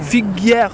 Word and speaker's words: Figuière: To speak to someone about knowing your audience, really understanding your audience in B2B content marketing, and that person is Nathalie Figuière. How are you? – I'm good. How are you? – Good. Did I say your last name correctly as well? – Figuière. – Figuière.